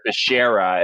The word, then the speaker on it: Becerra: And I mean, you sort of saw, some example of that with the Becerra announcement yesterday where Joe Biden announced him as Javier Barcarilla and then, Javier Becerra, and he couldn't even get his name right.